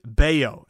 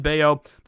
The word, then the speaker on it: Bello: And his last name is spelled B-E-L-L-O. It's not Bello, it's Bello. Again, make sure you roll your L's, roll your R's. But Bello, it's like silent. It's not Scott Bello, it's Brian Bello.